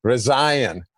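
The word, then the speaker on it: Rezaian: So I said, all right, remember it this way. It's like Ryan with a Z, Resign.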